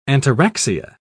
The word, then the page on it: ataraxia: British English